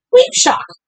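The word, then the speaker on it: Caoimhseach: I would have said... Coin first. But it's actually Queep Shock.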